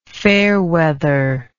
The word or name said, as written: fairweather